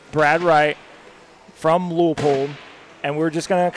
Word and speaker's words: Leupold: It's Leupold Optics. Am I saying that right? – It is Leupold, yes. – It's Leupold or Leopold? – It's not Leopold. It's okay. – Leupold.